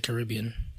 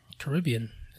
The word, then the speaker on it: Caribbean: I mean, if so, those drugs really did good for Pirates of the Caribbean. – Caribbean.